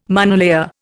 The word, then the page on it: monolayer: Indian